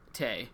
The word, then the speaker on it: Taye: Is it Ty or Tay? – Tay. I said it wrong.